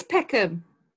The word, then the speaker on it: Peckham: Which sounds to me P-E-C-K-U-M. Peckham. And that's not how it is. – No, but it is Peckham. Unless you're Nigerian. – It's Peckham. – It is not Peckham. – P-E-C-K-H-A-M.